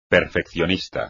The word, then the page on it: perfeccionista: espagnol